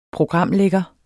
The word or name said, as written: programlægger